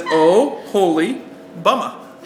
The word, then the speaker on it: Oholibama: How do you say it? It says, Oh, Holy, Bama.